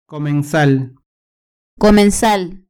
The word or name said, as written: comensal